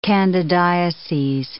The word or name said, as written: candidiases